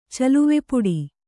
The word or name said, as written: ಚಲುವೆಪುಡಿ